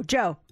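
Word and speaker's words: Djo: And the song is literally blowing up, but his singing like name when he posted his music online, it's D-J-O, Joe. But he says the D is silent.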